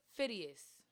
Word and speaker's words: perfidious: Profidious? – No, I ain't say pro. See what I'm saying? – Announce it. – Per. – Per. – Per. Perfidious. Rhymes with hideous. – Per. P-E-R. P-H. I. – He on the song. – D-E-O-U-S.